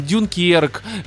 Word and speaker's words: Дюнкерк: Ну, реально, мне нолановские фильмы, там, Дюнкерк, э, ударение, насколько я помню, правильно, именно такое.